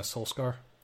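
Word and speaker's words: Solskjær: If you don't get out of here for Ole Gunnar Solskjaer, how do you say his last name? – Ole Gunnar Solskjaer.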